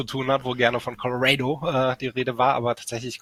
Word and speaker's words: Colorado: Na, es ist tatsächlich, Colorado ist richtiger als Colorado. Der Deutsche tendiert manchmal gerne dazu, Colorado ist etwas zu überamerikanisieren.